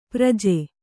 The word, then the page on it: ಪ್ರಜೆ: praje